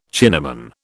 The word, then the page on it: Chinaman: British English